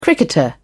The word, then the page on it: cricketer: British English